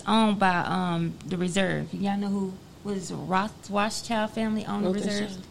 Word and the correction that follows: Rothschild: Am I saying it right? – Mm-mm. – It's Rothschild. – Yeah. – Okay, Rothschild. Okay, so you know that the United States is in like $1 trillion.